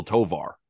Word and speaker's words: Tovar: And it rhymes with so far. His name is Ezekiel Tovar of the Colorado Rockies.